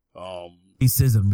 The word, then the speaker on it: Chisholm: I think I'm saying the name right, Shirley Chisholm. – Chisholm, right.